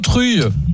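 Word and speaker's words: Truyes: On prononce, on dit Truille ou Truille ? – Truille. – Truille, hein. Ouais, je m'en doutais un peu.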